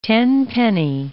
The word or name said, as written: tenpenny